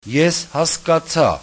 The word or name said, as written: eshaskatsa